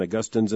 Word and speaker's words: Augustine: Old John Gerstner used to say, always remember that Augustine is in Florida and Augustine's in heaven. But I'll just pronounce it Augustine the historic way.